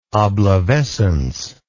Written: oblivescence

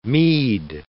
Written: meed

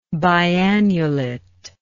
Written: biannulate

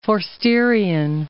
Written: Forsterian

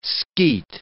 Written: Skeat

